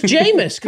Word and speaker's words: Jameis: Rhymes with famous Jameis.